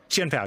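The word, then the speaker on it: Chien-Pao: I've actually played a decent amount of Chen Pao online. Am I saying that right? Chen Pao, Chen Pao? – Chen Pao, yeah, you're right.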